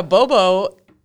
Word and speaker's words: Gborboe: And that's not how it's spelled. It's not like B-O-B-O, right? – No. – Okay. – So, yeah, Bobo, his name is spelled G-B-O-R-B-O-E. Bobo.